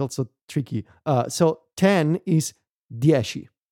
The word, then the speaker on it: dieci: In proper Italian pronunciation, and I'm going to try here my best, is dieci.